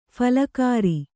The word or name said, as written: ಫುಲ್ಗರ